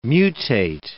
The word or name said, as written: mutate